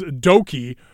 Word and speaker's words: Douki: Again, if you're listening to this, you probably already know that, but in case you didn't, the way you say D-O-U-K-I-E is Doki, or is it D-O-U-K-I, excuse me. The way you pronounce it is Doki, like you're saying okie dokie.